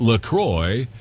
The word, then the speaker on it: LaCroix: So officially, it's pronounced LaCroix, so it rhymes with enjoy.